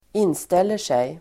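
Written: inställer sig

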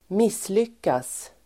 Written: misslyckas

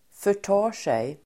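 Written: förtar sig

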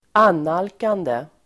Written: annalkande

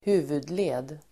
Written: huvudled

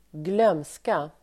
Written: glömska

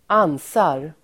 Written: ansar

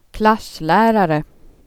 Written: klass

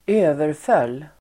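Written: överföll

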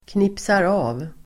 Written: knipsar av

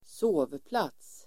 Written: sovplats

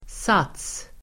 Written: sats

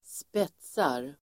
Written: spetsar

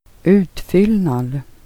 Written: UT

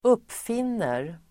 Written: uppfinner

